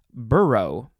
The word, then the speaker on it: burgh: It is spelled B-U-R-G-H, and it tells me that it is pronounced burrow. There's no O in there, but that's how it's spelled. So it's a noun from the 12th century, and the synonym is burrow, B-O-R-O-U-G-H.